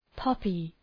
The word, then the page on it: poppy: ‘pɒpı